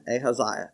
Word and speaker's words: Ahaziah: Remember Azariah? How do you say that? Huh? Ahaziah. Okay.